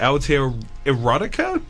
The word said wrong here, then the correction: Aotearotica: Aotearotica. – Right. Thank you.